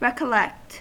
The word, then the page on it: recollect: American English